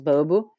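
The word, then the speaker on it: Baubô: Et alors le nom, comment est-ce que vous l'avez trouvé Parce que ça se, ça se prononce Baobo, c'est ça Oui, voilà, ça s'écrit B-A-U-B-O, B-A-U euh, accent circonflexe, et, euh, et, ça se prononce Baobo.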